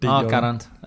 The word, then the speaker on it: Geraint: Nej, det er mere, at man udtaler det rent faktisk med G, og ikke siger altså D. – Geraint.